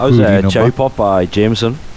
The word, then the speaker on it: JMSN: Going for someone called JMSN, but apparently it's pronounced Jameson.